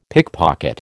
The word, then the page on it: pickpocket: American English